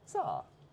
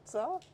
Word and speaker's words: Tsar: Labour, on Saturday, it was announced, have hired a whistleblowing Tsar. Is that pronounced right? Tsar? Tsar? – I think that's about right, I think.